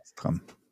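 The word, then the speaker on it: Szass Tam: Sastram. Sastram. Okay. – Ich habe gerade versucht, wie er ausgesprochen ist. – ja, Saß. S-Z-A-S-S-Tam. – So, so heißt er.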